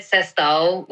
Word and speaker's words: sextou: I said sextou, which is, it's spelled S-E-X-T-O-U.